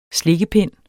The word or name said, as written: slikpind